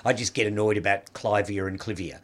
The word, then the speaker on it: Clivia: You've got to say Clivia. – Yeah, I still say Clivia. – Yeah. – You're the honest Clive of India, so it has to be Clivia.